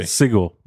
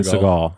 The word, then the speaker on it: Seagal: Seagal, right? That's how you say it. Steven Seagal. – Yeah. Seagal, yeah. Seagal. – I always get it because it's spelled with S-E-A, so it's like Seagal.